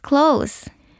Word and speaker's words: close: 惜しいは That was close. Close は近いという意味です。クローズではなくクロースと発音します。